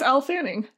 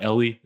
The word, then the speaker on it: Elle Fanning: Yeah, it's Elle Fanning. – Okay, it's not Ellie, it's Elle. – No, correct.